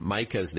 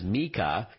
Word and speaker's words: Micah: So if Siri says something wrong, like pronouncing your friend Micah's name as Mika instead of Micah, then select the person in your contacts and tap Edit in the top right corner.